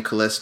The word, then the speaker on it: Caless: You can find me on Facebook under my name, Colesse Davis, and that's spelled C-A-L-E-S-S, last name Davis.